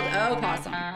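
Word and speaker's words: opossum: But it's spelled O-possum. – The O is silent. silent.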